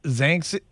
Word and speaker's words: Xanthe: She, her name is Xanthe, spelled X-A-N-T-H-E. – X-A-N-T-H-E, and it's pronounced Zanx. What is it? – It's pronounced with a Z in front of it, like Z-A-N-T-H-E. So Xanthe.